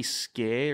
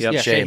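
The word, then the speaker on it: Skjei: Brady Skay or Shay? – Shay.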